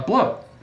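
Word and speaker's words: blót: But there's a thing in a lot of like uh, rituals with Norse uh, paganism called like a uh, bloat, which is like uh, spelled like B L O T.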